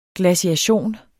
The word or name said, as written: glaciation